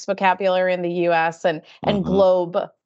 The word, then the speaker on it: GLOBE: I was proud of a personal triumph last week when the OECD responded to me on LinkedIn to confirm that we are not pronouncing the E in GLOBE for the Global Anti-Base Erosion Information Return. Every time someone said GLOBE, I would just think of Pee Wee Herman, and I couldn't shake that image, so I needed closure.